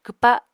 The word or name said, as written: kwpa